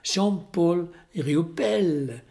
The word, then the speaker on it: Jean-Paul Riopelle: Jag vet inte hur det ska uttalas. Jean-Paul Riopelle. Säg det noga. Jean-Paul Riopelle. Rio-Pelle.